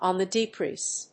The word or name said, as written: on the decrease